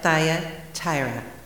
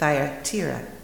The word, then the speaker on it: Thyatira: The fourth letter, the church at Thyatira. Now, I've also heard it Thyatira. You can pronounce it either way, I guess.